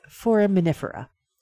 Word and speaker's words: foraminifera: I know how to say this. It's foraminifera.